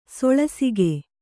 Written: ಸೊಳಸಿಗೆ

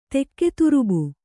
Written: ತೆಕ್ಕೆತುರುಬು

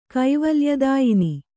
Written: ಕೈವಲ್ಯದಾಯಿನಿ

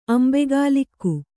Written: ಅಂಬೆಗಾಲಿಕ್ಕು

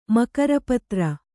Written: ಮಕರಪತ್ರ

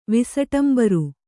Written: ವಿಸಟಂಬರಿ

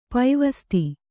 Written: ಪೈವಸ್ತೆ

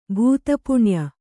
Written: ಭೂತಪುಣ್ಯ

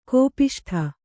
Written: ಕೋಪಿಷ್ಠ

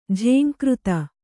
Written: ಝಂಕೃತಿ

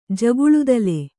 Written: ಜಗುಳುದಲೆ

